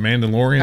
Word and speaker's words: Mandalorian: Is it Melandrian? I don't know. I see it written. I don't know how to say it. – Are you talking Mandalorian? – There you go.